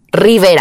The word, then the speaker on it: Rivera: So, you know, I had to like, for example, Riviera, like Rivera, Rivera right. in Spanish. In English, they said, no, no, can you pronounce a little bit more American? Rivera.